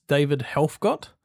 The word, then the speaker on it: David Helfgott: This song features Australian concert pianist David Helfgott.